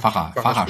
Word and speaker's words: Vacha: Vacha spricht man das aus.